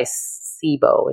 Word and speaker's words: SIBO: What is, you know, and like you said, IBS is mainly caused by SIBO, is how you pronounce it, correct? – Yes.